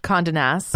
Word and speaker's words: Condé Nast: How do you say this? Is it Cond Nest? – Condé Nast.